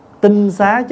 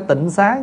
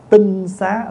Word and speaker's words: tinh xá: tinh xá chứ không phải tịnh xá nha. Bây giờ mình đọc hơi trại là chữ tịnh xá đó, chứ đúng đọc là đúng cách cách đọc là tinh xá, là cái nhà trong sạch gọi là tinh xá.